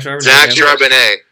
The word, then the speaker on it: Charbonnet: Yeah, Zach Charbonnet.